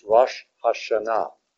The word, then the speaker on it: Rosh Hashanah: The correct pronunciation is Rosh Hashanah.